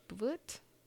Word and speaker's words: Bwt: How would you say that? B-W-T? Boot? – Okay. Yeah.